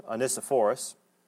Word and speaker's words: Onesiphorus: The Lord grant mercy to the house of there's two pronunciations, and I'm going to go with Onesiphorus.